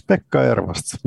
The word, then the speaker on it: Pekka Ervast: Is it Pekka Ervast? – Yeah, that's good. All recognizable. Yes, Pekka Ervast. You pronounced it pretty well.